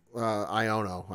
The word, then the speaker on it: Xiono: Yeah, Senator Ion? – Iono, I think is how you say it. X-I-O-N-O.